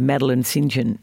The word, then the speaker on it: Madeleine St. John: Well, I've finally delved into the world of Madeleine St. John. Is that how you pronounce it? – It is, actually.